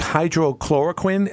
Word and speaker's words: hydroxychloroquine: You know, Donald Trump was talking about, and please help me with the pronunciation here, it's hydroxychloroquine.